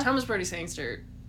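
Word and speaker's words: Thomas Brodie-Sangster: Thomas Brody Sangster, if I'm saying his name right. – Yes.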